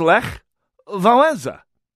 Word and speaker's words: Lech Wałęsa: and the E has a little little scooch underneath it that means you pronounce it. Eh. The letter E is pronounced eh. So it's did I, I make that sound? Yeah any actual Polish speakers out there can set me straight. I'm, I'm certainly not an expert, but and and ch is not pronounced ch in Polish; it's pronounced ch. So the name is Lech Wałęsa.